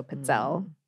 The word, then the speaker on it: pizzelle: that double Z in Italian really should be like a soft T, like pizza. So pizzelle or pizzelle would be the more correct pronunciation.